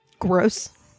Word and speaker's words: Gross: Gross.